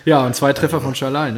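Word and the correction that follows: Szalai: ne? – Ja, genau. Salai. – Oder Salai, ja, sorry. Ja. Falsch ausgesprochen.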